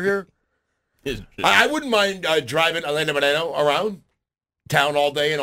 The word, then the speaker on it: Helena Moreno: Out of all the New Orleans City Council members, my favorite is Helena Moreno. Hold on. I should say that properly. I shouldn't say that like a gringo. Helena Moreno.